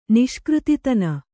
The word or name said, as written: ನಿಷ್ಕøತಿತನ